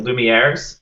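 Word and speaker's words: Lumière: I'm worried about pronouncing the name right, the Lumieres, am mm-hmm.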